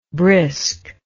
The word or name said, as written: briskness